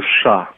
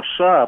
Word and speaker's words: США: Причем, да, причем преподаватель так неразборчиво говорил, и у него все время не США, а США получалось, агрессия США. – Он просто использовал старое произношение, тогда, я напомню, не США говорили, а именно США. Тогда вот такая была норма принята, и он, конечно, человек старой закалки, это чувствуется.